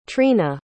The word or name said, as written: treena